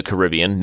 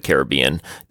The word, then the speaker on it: Caribbean: For those of you who don't know where that is, it's in the Caribbean, not pronounced Pirates of the Caribbean. Disney ruined the way we pronounce the Caribbean.